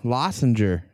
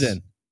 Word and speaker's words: Lorenzen: And your boy, Lossinger, is pitching.